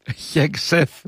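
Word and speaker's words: Χέγκσεθ: Ναι, και ο Χέκ Σεφ, για να το προφέρω πλέον σωστά, όπως με διορθώνουν, και ο Ρούμπιο, όλοι έχουν τα μούτρα κάτω κάπως.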